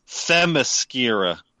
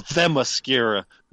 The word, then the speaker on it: Themyscira: It's T-H. Look, motherfuckers, I've been teaching a kid how to read, right? Okay. I know how to pronounce shit. The T-H is all right? So that's Themyscira.